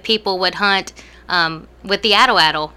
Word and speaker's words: atlatl: What is it? Atlatl program? – Atlatl. Yeah, okay. No one ever knows how to pronounce that.